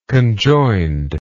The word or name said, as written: conjoinedly